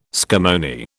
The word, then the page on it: scammony: British English